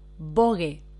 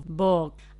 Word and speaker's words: Vogue: Ella pronunciaba algo más o menos como Vogue. Así que en español, Vogue.